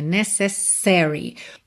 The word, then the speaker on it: necessary: O que não pode é você não dar a ênfase necessária na primeira sílaba. Ou seja, você dizer necessary, necessary, não fica legal, tá?